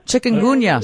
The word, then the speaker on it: Chikungunya: I'm not sure I'm saying it right. Chikungunya? – Chikungunya. – Chikungunya. – See? – Chikungunya. – Hello. – Yes. – Very good.